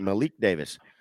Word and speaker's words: Malik Davis: That's Malik Davis, nobody's heard of him, and I may have even just screwed up his name, but nope, that's exactly how you say it, Malik Davis.